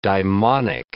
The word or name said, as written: daimonic